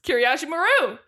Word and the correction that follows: Kobayashi Maru: Kobayashi Maru.